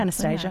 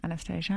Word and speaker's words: Anastasia: Am I saying that correctly? – Anastasia. – No, Anastasia.